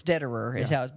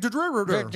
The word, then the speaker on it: Dederer: It's Dederer, but I always pronounce it wrong. So thank you for helping me with that. – Dave Dederer.